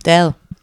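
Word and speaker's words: Dalle: Deli ist Deli, richtig? Spricht man das so aus? – Dell. – Dell.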